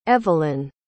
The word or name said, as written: evelynn